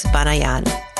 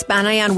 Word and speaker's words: Banayan: Here's Alex Banayan. Alex Banayan, welcome to So Money.